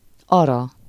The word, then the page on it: fiancée: fijɑ̃.se; France